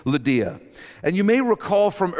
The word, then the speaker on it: Lydia: It's not Lydia, it's Lydia is her name.